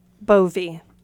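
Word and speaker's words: Bowe: And how do you pronounce your last name? – Bovey. – So it's Rich- a German pronunciation.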